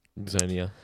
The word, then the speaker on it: Ixonia: Izonia, it's an X, didn't you say? – Yeah, but it's an X that can be like xylophone, pronounced as a Z.